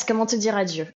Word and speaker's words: Comment te dire adieu: Yes, comment te dire adieu? – Yeah, comment te dire adieu.